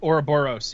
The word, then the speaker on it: Ouroboros: But it looks like that game, to be honest, but they're calling it, hopefully I can pronounce it right, Ouroboros? – Ouroboros, yeah. – Ouroboros? – Boros. You were right the first time.